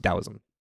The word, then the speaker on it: Taoism: It's spelled with a T, Taoism, but it's pronounced Taoism, which has work recorded as early as around 1000 BC.